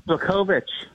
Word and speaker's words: Błachowicz: I guess the co-main event is Dominic Reyes against, and I'm going to struggle to say this, Jan Błachowicz. You like that pronunciation? – Błachowicz.